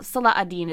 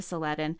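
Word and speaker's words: Salah ad-Din: The binary begins with the Arab conquests in Europe and is continued in the medieval interactions between Saladin and, well, Salah-ad-Din is really the more better way to say his name, but I think it's been anglicized to Saladin, between Saladin and the Norman Richard III.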